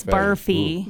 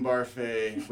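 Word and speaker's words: Barfée: It's Barfy. Ooh. – Barfy. – One of the jokes during the play is they keep saying his name wrong. So okay. They say Barfy. But it's French. It's Barfay.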